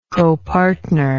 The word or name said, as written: copartnership